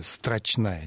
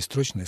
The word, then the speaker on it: строчная: Кроме одного, ударение все-таки на втором слоге «строчная», не «строчная», «строчная» буква.